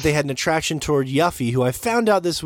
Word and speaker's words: Yuffie: We say Yuffie, which is her name.